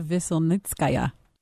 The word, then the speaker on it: Veselnitskaya: Natalia Veselnitskaya. – Oh, I didn't say it with the flair that you did for crying out. – Her name's not Natalie. It's Natalia. – Natalia Veselnitskaya.